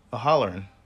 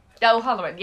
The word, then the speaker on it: O'Halloran: I don't know how to pronounce his name. Holloran? – Let me see. – I wrote it down. Holloran. – O'Halloran. – O'Halloran, yes. But Jeff Anderson, Jason Mewes, obviously Kevin Smith.